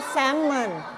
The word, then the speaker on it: Salmon: Salmon ไม่ออกเสียง L นะ Salmon